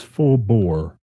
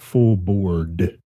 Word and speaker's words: full bore: It is full bore, not full board. Full board is full. – Is full breakfast. Bore. Full bore means full speed ahead.